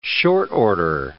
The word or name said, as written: short-order